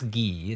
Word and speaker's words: Guy: I know it's Guy. It's spelt Guy, but it's pronounced in the French way.